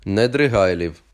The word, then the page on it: Недригайлів: n̪ed̪rɪˈɦɑi̯ʎiu̯